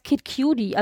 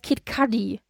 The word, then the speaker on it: Kid Cudi: ich habe gedacht, er heißt Kid Cudi, also von Cute, aber heißt er gar nicht, er heißt Kid Cudi.